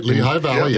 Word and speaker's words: Lehigh Valley: who are in Lehigh Valley. Am I even saying that right? – That's right. Lehigh Valley, in Pennsylvania.